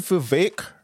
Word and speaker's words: Vivek: I'm glad you said Vivek rhymes with cake because the mispronunciations of his name are so... Anyway, I'm glad you said Vivek like cake because his name is frequently mispronounced.